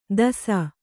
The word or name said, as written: ದಸ